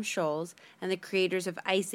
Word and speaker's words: Schulz: No, it's not. There's no T. – I think it's still pronounced Schulz. – Nope, it's Schulz.